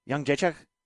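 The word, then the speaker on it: Jędrzejczyk: I've noticed, you know, people have been butchering her name for years. It took us a long time to figure out that it's Young Jacek.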